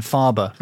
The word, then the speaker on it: Faber: Probably should have been Faber. That's probably how Germans would pronounce it.